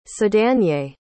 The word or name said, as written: sedanier